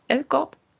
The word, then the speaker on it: Elgort: Wie spricht man den aus? Elgort? – Ich würde es auch so aussprechen.